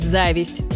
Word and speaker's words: зависть: Все это замечательно, но может вызвать зависть с ударением на первом слоге.